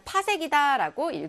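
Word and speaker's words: 파섹: PC로 쓰고요. 우리가 읽을 때는 파색이다라고 읽어주시면 돼요.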